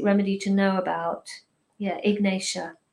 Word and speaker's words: Ignatia: Ignatia. I-G-N-A-T-I-A.